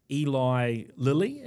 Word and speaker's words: Eli Lilly: And this is what happened with Eli Lilly. Is that the correct pronunciation? – It sounds right.